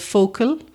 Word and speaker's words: Fochail: at a place called Focal, which is spelt F-O-C-H-A-I-L, that's near the village of Killala in County Mayo.